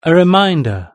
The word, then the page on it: a reminder: British English